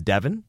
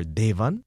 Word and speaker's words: Devan: And my name is spelled Devan, but it's Devan. That's mm-hmm. how it's always been pronounced. Or Devan.